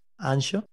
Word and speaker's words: Anxo: Ancho se escribe A-N-X-O, ¿vale? Ancho.